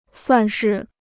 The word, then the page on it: 算是: suàn shì